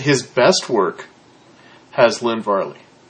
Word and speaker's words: Varley: How much of this, though, is Lynn Varley? Varney. How do you say his name, Brett? – Her? – Her. Yeah, please. – I believe it's Varley, isn't it? – Varley, yeah. So how much of this,